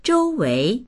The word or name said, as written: zhou1wei2